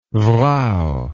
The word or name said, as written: vrouw